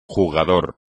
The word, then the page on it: jugador: Spanish